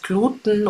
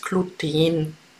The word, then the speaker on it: Gluten: Und es ist immer die Frage, heißt es Gluten oder heißt es Gluten? Und da kann ich dich beruhigen, es ist beides richtig. Du kannst es aussprechen, wie du möchtest.